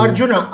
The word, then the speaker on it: Arjuna: Ar- Arjuna in Kuru. – Arjuna.